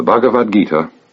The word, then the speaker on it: Bhagavad Gita: The Bhagavad Gita, or Bhagavad the Lord Gita Song, the Song of the Lord. It is spelled B-H-A-G-A-V-A-D, Bhagavad, Gita, G-I-T-A.